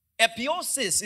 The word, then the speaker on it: epiousios: So the Greek word that was used throughout the Bible is hemera, H-E-M-E-R-A. That's what daily is. But this Greek word, I don't want to twist your tongue, so I'll just spell it to you, is epiosis. It's spelled E-P-I-O-U-S-I-O-S.